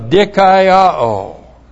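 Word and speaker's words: Dikaiō (δικαιόω): Then we have the perfect passive indicative of dikaiō. D-I-K-A-I-O long O. Dikaiō.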